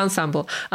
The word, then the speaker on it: ensemble: Ensemble. – Co jeszcze? Ensemble. A ensemble to jest zespół. Pisze się ensemble.